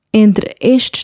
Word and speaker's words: entre estes: Eu estou aqui indecisa entre estes dois. Entre estes dois. The E at the end of entre is silent. The E from estes.